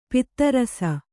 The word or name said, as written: ಪದರಿಸು